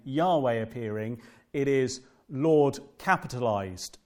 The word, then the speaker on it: Yahweh: And so what did happen in history, and some of the older Bible translations will write Jehovah. That's a mispronunciation. It's an error. It shouldn't be at all pronounced like that. The closest we think is the pronunciation is Yahweh.